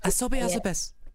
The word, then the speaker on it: Asobi Asobase: Asobi Asobase.